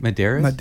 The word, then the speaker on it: Madeira: Madeira, how do you say it? Madeira. – Yeah, there's Madeira. – A roll in the R.